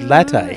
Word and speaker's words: latte: To me, that says latte.